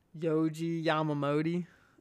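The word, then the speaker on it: Yohji Yamamoto: Let me see if I can pronounce his name correctly. It's like Yoji Yamamoto. – Okay. That wasn't bad.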